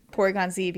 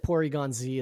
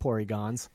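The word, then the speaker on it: Porygon-Z: Um, do you think that Porygon Z is actually pronounced Porygons? – No.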